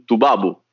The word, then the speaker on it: Tubabu: All'orizzonte un Tubabu, giusto la pronuncia? Corretto.